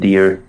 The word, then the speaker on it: Dhir: It's the Jason Dirr Podcast. Last name is spelled D-H-I-R. You can find me on Instagram at Jason Dirr, D-H-I-R.